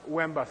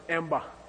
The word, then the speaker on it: wenba: Wenba means, sometimes in Tibetan it's pronounced wenba. See wenba? Wenba? Okay, and sometimes it's pronounced emba. Okay, depends on what part of your Tibet you're from.